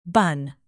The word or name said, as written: bun